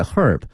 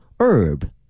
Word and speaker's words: herb: 但同学们注意，这个草药呢不同的国家哈，就美国和英国它的念法是不一样的，英国人会把这个 H 这个 herb 这个 h 这个音呢给念出来 herb，但是美国人啊会把这个第一个字母 H 给它吞进去，直接念成 herb 啊，所以呢大家听不同国家的人念这个词的时候呢要小心了， herbs herb as wormwood is a magical herb，或是 herb 都行哈，是一个神奇的一个草药。